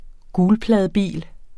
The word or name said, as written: gulpladebil